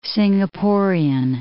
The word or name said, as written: Singaporean